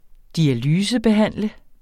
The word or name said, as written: dialysebehandle